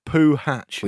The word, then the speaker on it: Puhacz: Winfield says the best name of the Euros has to be that of Polish player Puhacz, who, at least according to our commentators, is pronounced literally Poo Hatch.